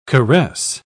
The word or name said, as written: caress